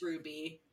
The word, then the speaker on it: RWBY: Yeah, it's just pronounced Ruby.